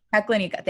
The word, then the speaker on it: Teklanika: And he crosses the Telankia. Oh, I know I said that wrong. Teklanika. Fuck, I do not know. – Good job. – Teklanika, thanks, I sounded it out.